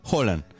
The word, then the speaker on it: Haaland: ¿Lo escuchaste cómo se pronuncia? Haaland.